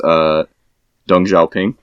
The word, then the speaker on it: Deng Xiaoping: And the boss or whatever that like appears randomly throughout the game is Deng Xiaoping, right?